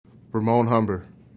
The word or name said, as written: Humber